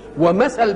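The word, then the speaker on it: مَثَل: مثل بكسر الميم وسكون الثاء مش كده، ومثل بفتح الميم وفتح الثاء. يبقى إيه الفرق بين مثل ومثل؟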